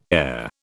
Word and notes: heir: British English

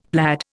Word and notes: plaid: Indian

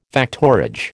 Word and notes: factorage: American English

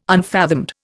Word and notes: unfathomed: Indian

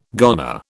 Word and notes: goner: British English

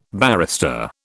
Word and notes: barrister: British English

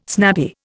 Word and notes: snappy: Indian

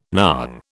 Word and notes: nong: British English

